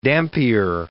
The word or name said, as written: Dampier